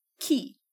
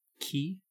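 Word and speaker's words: ci: The singular is key. – Key? – Mm-hmm. C-I, pronounced like what you put in a lock.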